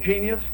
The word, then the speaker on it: Genius: LeBeau, Genius LeBeau.